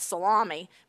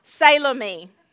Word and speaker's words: Salome: I listened to it online to try to get it right because, you know, I want to call it Salami, but that's not her name. Salome.